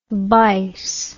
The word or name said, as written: bice